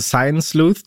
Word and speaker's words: Science Sleuths: Ich habe durch dich einen schönen Begriff kennengelernt, der ist nicht ganz leicht auszusprechen. Ich versuche es mal. Science Sleuths. Die gehen quasi gegen Betrügereien vor, ne? – Genau, diese Science Sleuths, du hast es schon sehr, sehr gut ausgesprochen. Das sind sogenannte Wissenschaftsdetektive.